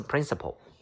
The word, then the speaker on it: principle: 下面一个是 principle。你看它的发音像不像我们校长那个单词 principal？他们两个人的发音是一模一样的，只不过这个的拼写是 p l e，那个校长是 p a l principal。